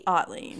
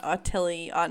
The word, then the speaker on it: Otley: Otley, not Otilly, not Otilly. Like, Otley.